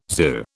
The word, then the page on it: zoo: British English